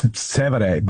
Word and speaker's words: Trastevere: Yeah, Trastevere.